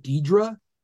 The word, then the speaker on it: Dedra: Like D, well, it's not spelled this way in the show, but in real life, it'd be like a D-E-I-D-R-E. That, like Dedra. – Yeah. – But it's not spelled that way. It's D-E-D-R-A.